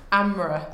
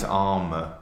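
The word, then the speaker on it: Ámr: Amra. – I think it's Armour. – Armour. Great. Armour. That's A-M-R, and there's an accent on the A.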